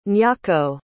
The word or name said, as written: nyako